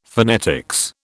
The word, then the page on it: phonetics: British English